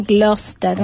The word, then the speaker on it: Gloucester: Sí, Edward Jenner nace en 1749 en Inglaterra, en una comarca, porque ahora su nombre cambió, se pronuncia Gloucester, ¿no? Como se escribe que es Gloucestershire, pero es la comarca de Gloucester, así se debería decir.